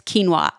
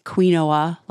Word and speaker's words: quinoa: Quinoa is that Q-U-I-N-O-A food that you don't know how to pronounce sometimes. It's quinoa. I didn't know that for a while and I was calling it quinoa, like back in the 90s or something. It's quinoa and I like it.